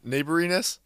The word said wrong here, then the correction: neighborliness: No, it's like neighborliness. Neighborliness. – I think you're stressing the wrong syllable.